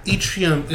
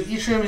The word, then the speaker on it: Yttrium: Yttrium is the... I think it's, that's what it's pronounced. It starts with an Y.